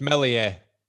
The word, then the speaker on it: Meslier: But you don't pronounce the S. Come on. It's Meslier.